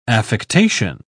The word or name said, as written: affectation